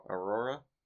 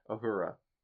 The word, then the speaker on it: Uhura: Aurora? – Uhura.